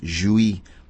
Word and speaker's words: Jouis: And they're called, the group's name is spelled J-O-U-I-S, and it's pronounced Jouy, like jouissance, right? And we're going to hear a track from an album called called Mind Bond, B-A-H-N.